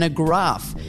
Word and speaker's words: agraffe: We all have experience with an agraff. A-G-R-A-F-F-E,